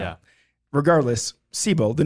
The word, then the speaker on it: Sebile: It's spelled S-E-B-I-L-E. So Sabile? – Sabile? – Sabile sounds terrible, though. – Sabile does not sound right. Yeah. – Sebel? – Third option. – Sebel. – Yeah. – Okay. Good job, Nick. Sebel. I think one of the biggest details that came out of... – Is that how you pronounce it? – Did you hear the two options that I just threw out there? Wasn't Sebel? I think Sebel's right.